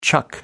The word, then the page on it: chuck: American English